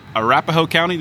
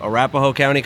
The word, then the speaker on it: Arapahoe: Is running for sheriff in Arapahoe County. Did I say that right? – Yep. Arapahoe County, Colorado.